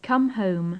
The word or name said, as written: come home